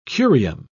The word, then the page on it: curium: British English